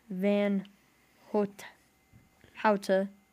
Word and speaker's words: Van Houta: By the way, last name is pronounced Van Houta. Like out.